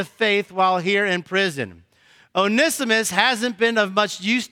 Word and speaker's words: Onesimus: And the name is Onesimus.